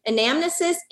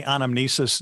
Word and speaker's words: anamnesis: Because I've heard it pronounced anamnesis and anamnesis. – Yeah, both. I've heard it said both ways. I usually say anamnesis, and I don't know why.